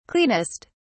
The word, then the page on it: cleanest: British English